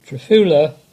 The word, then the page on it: Trefula: English